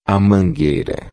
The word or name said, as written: mangueira